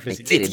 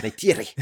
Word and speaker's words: Neytiri: Som hon påpekar, Neytiri! Neytiri, du måste uttala r -et. Du måste uttala r -et. – Neytiri.